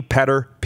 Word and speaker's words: Petter: My name is Rodney Petter Peterson. It's Danish. Don't ask. Petter Gordon. Or the North American pronunciation would be Peter Gordon.